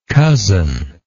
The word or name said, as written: cozeningly